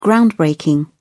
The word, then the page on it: groundbreaking: British English